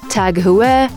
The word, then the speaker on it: Tag Heuer: A lot of people might mistake it by saying Tag Heuer, Tag Heuer, Tag Heuer. It's actually Tag Heuer.